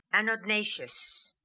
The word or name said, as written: anodnatious